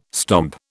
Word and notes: stomp: British English

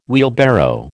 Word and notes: wheelbarrow: American English

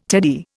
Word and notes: teddy: Indian

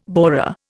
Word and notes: borer: Indian

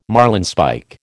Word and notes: marlinspike: American English

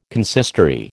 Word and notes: consistory: American English

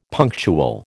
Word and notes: punctual: American English

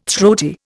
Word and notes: throaty: Indian

